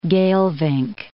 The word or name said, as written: Geelvink Bay